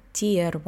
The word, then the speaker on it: Tier: Виза Tier 1 не Тайр, мы там, кстати, все время оговаривались.